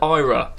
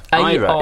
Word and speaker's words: Aira: This is the Roland Ira series. – I don't understand the pronunciation. – Ira. – Aira. It's spelled with an A.